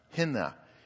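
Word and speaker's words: hina (ἵνα): The word "that" in the Greek language is a little word pronounced hina.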